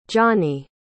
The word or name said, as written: johnnie